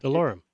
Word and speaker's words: DeLorme: How do you pronounce the company name? – DeLorme. – DeLorme. – Just DeLorme.